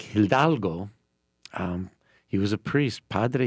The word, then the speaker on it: Hidalgo: Hidalgo.